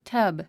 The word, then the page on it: tub: tʌ́b; タァブ